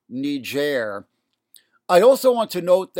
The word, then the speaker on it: Niger: I have been admonished in certain quarters to use the French pronunciation Niger, which I failed to do on our last podcast discussing the crisis in the country on August 12th.